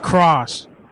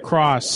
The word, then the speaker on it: Cross: This is Cross. – Say it again, spell it. – Cross, C-R-O-S-S. – C-R-O-S-S.